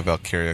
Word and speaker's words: Valkyria: Yeah, Valkyria.